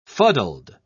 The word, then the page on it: fuddled: British English